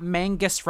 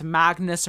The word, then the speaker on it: Magnus: So he explicitly is like, it's not Mangus rhymes with Angus, it's Magnus rhymes with Swagnus, which I don't know.